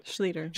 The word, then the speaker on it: Schluter: Please help me so I say Schluter. – It correctly. – Schluter. – It's been butchered much worse. – Yeah, it was pretty good. Like sweeter.